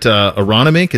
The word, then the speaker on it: Aronimink: This is at Aronimink. Is that how you say it? – Yeah, that's how I was going to say it.